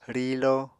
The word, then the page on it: rilo: Apatani